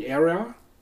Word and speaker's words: Era: Es gibt eine Doku, die heißt Golden Era. Wie spricht man das aus? Golden und dann E-R-A. – Era. Ja, Golden Era. Era. Ja, würde ich auch sagen.